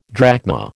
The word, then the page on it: drachma: American English